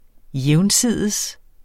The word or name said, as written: jævnsides